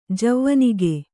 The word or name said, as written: ಜವನಿಕೆ